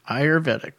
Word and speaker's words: Ayurvedic: Thai fish cake with coconut sauce, crispy duck roll, herbal Ayurvedic soup. – Ayurvedic. – Going to fix me on all those mispronunciations. The ones I know. – Herbal Ayurvedic soup. – Ayurvedic.